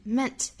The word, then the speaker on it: meant: One cannot deny who one is 连读 nis who one is，再看后面的 meant to be Meant to be, meant 尾部特也省掉，那 meant 这个词注意元音是一个 a 的发音，